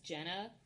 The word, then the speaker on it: Jeanna: And there's this woman I follow who is, I believe it's pronounced Jenna, J-E-A-N-N-A, Kadlec, K-A-D-L-E-C.